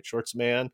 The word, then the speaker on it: Schwartzman: Schwartzman.